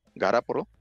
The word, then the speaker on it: Garoppolo: Hay alrededor de cuatro a seis equipos de la liga que pueden estar interesados en los servicios de, Jimmy Garoppolo, Garapolo, porque él así lo pronuncia.